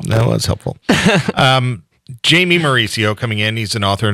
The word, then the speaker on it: Mauricio: Let's have you do it. M-A-U-R-I-C-I-O. – Mauricio. – Mauricio. Thank you.